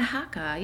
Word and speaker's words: haka: Oh, yeah, the haka.